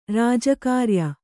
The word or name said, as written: ರಾಜಕಾರ್ಯ